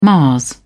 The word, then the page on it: mars: British English